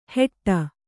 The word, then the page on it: ಹೆಟ್ಟ: kannada